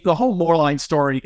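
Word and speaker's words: Moerlein: But how does Rheingeist compare to, now I may get the name wrong, so you have to correct me on this, more lean? – More line. – More line, okay.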